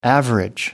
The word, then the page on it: average: American English